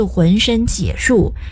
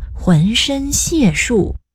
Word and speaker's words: 浑身解数: 哎，那句话不是浑身解数，得读作浑身解数。 – 明明就是解释的解，左边是角落的角，右边上面是刀，下面是牛。 – 这个字有三个读音，一个确实读解，解释、解说、讲解，还有一个是借，第四声，借是押送的意思，比如借送犯人就是押送犯人，又或是借运就是押运，比如借运军用物资。